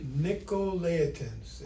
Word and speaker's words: Nicolaitans: Nicolaitans.